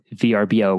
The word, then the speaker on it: Vrbo: So obviously now with the emergence of Airbnb and VRBO, which apparently you're supposed to say Vrbo, which I've never heard, but apparently that's the right pronunciation of that.